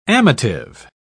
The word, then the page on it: amative: British English